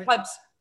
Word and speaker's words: plebs: Like, is it plebes or is it plebs? Because there were people in Rome called plebes, weren't there? The poor people. – Am I saying that right? Plebs. You're right. Plebs. You're saying it right. Plebs is the right pronunciation.